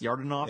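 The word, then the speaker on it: Yordanov: Is that how you think it's pronounced? Yardanov? – Yeah, that works.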